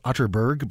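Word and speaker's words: Atterberg: It's spelled Otterberg, but pronounced Otterberg, just in case you are Googling.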